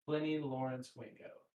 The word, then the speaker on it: Plennie Lawrence Wingo: Plennie L. Wingo? – L is Lawrence. That's the only like. – Plennie Lawrence Wingo. Yes. – Yeah. – Correct.